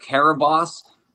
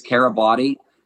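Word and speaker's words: Kiribati: It's actually called Karabas. Most people mispronounce it. They think it's Karabati.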